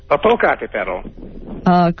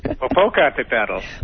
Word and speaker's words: Popocatépetl: Popocatépetl. – Could you repeat that, please? – Popocatépetl. – Popocatépetl, which is spelled P-O-P-O-C-A-T-E-P-E-T-L.